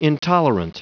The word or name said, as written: intolerant